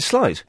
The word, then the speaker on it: sleight: Yes, Is it sleight of hand or sleight of hand? How do you pronounce it? – Well, you pronounce it sleight.